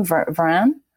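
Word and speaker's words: Varane: Varan.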